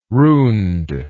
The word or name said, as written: runed